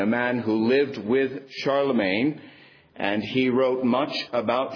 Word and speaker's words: Charlemagne: This M-A-G-N-E in his name, the G is silent, Charlemagne, that M-A-G-N-E is derived from the Latin magnus, meaning great.